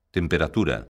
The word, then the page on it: temperatura: galega